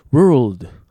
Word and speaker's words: world: World はい。 World L の音に気をつけてね。